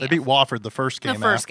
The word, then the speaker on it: Wofford: Virginia Tech beat Wofford. Am I saying that right? Wofford? – They beat... – You're our South Carolina expert. – I thought they beat... – Yeah, it is Wofford.